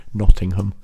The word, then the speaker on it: Nottingham: Well, I should probably pronounce it properly for our non-English listeners. Nottingham.